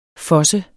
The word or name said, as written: FOSSET